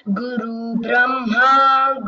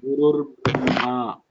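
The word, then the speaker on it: Gurur Brahma (गुरुर्ब्रह्मा): Tasmai Shri See, you should practice the pronunciation properly. Gurur Brahma, Brahma, Gurur Vishnuhu, Gurur Devo Maheshwaraha. So there are subtle improvements which can be made in your pronunciation.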